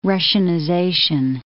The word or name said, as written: Russianization